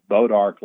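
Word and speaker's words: Bois d'Arc: what's called Bodark Lake. Doesn't look like that's the way you would pronounce it if you saw it spelled, but it was a brand new lake that the state of Arkansas had built down in southwest Arkansas.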